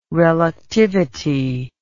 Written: reluctivity

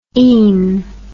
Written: ine

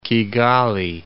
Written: Kigali

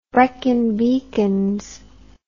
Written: Brecon Beacons

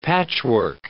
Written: patchwork

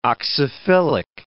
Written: oxyphilic

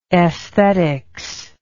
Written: aesthetics

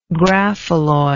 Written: graphalloy

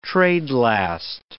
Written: trade-last

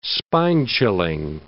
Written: spine-chilling

